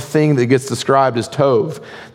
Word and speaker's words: tov: called tov, T-O-B, T-O-V, we would pronounce it tov.